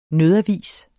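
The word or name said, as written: NØDAVIS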